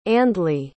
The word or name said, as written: ardleigh